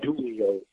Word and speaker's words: Dulio: Professor John Dulio. D-U-L-I-O.